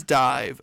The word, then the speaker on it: DIIV: It is, in fact, I believe, D-I-I-V, but it was Dive, because they originally called Dive